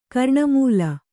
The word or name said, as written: ಕರ್ಣಮೂಲ